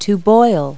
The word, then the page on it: boil: verb